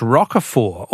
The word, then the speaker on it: Roquefort: The French Roquefort, also with a silent T, with a silent T, is very smelly.